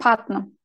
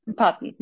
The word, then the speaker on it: Putnam: Martin Short e, yani Oliver Petrum mu? Petnam mı? Broadway'de başarılı bir yönetmen. Putnam. Putnam.